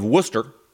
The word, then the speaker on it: Worcester: At that time, she owed the city of Worcester, and I am pronouncing that correctly.